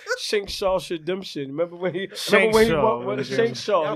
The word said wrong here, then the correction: Shawshank Redemption: Y'all both said it wrong. It's Shawshank Redemption.